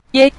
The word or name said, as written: ye5